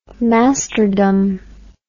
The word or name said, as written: masterdom